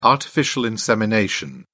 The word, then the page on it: artificial insemination: British English